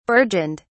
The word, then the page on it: burgeoned: British English